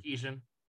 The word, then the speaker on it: Sarkisian: Um, Sarkisian.